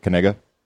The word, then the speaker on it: Knigga: K-N-I-G-G-A. And that's how you pronounce it, Kniga.